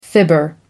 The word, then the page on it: fibber: American English